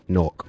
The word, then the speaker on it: Newark: And without question, Newark. Oh, pardon me. I said it wrong. Newark.